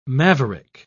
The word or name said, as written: maverick